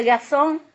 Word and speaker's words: garçon: And the garçon means boy, no? Mm. Written also with garçon, huh? With the N at the end. How do you say it now in French? Yeah. Garçon! You got it!